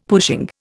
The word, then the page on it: pushing: Indian